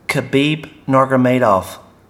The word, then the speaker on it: Khabib Nurmagomedov: Did I say that right? – I think you got it. – I've been practicing. – Try it one more time. – Khabib Nurmagomedov.